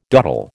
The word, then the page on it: doddle: American English